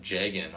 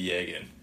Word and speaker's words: Jagan: His name is actually Yagan, or Yagan. Still don't know how to say it. It's with a Y, though. It's not with a Y, but Y sound instead of the J.